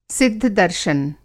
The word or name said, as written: सिद्ध-दर्शन